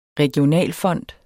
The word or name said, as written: regionalfond